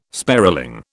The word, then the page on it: sparling: British English